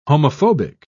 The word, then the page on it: homophobic: British English